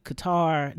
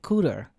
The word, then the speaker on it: Qatar: Yeah, it was some lady, I think I saw her this morning, and she said Qatar. No, she said Cooter. And I was like, where is Cooter? And I was like, Qatar? And I was looking and said, am I saying it wrong?